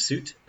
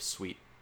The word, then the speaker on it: suite: Yeah, so the type of projects we're currently engaged in is there's Soil Studio, which is a creative suite of programs for assisting in all aspects of the creating process. – It's pronounced creative suite.